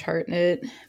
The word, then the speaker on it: Hartnett: She wanted to get stuck in Barrow so she can get some Josh Harnett D. – Josh Hartnett. But that didn't happen. – Is that how you say it? Hartnett? – Yeah. – It's Hartnett. Hairnet.